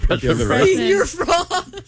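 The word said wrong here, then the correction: Señor Frog's: Senor Frog. – Whatever. I didn't have a problem with the way you said it out. – Well, he's in Mexico. You know, you gotta pronounce it right.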